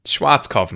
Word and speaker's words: Schwarzkopf: In German, we pronounce it Schwarzkopf. – Kopf? Nah. Schwarzkopf. Schwarzkopf, mate. – Blackhead. Schwarzkopf. English native speakers usually pronounce it Schwarzkopf or Schwarzkopf or something along those lines.